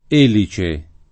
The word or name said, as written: elice